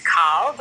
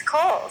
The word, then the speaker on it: Coll: And my last name is spelled C O L L. It should be pronounced Call, but it's Cole.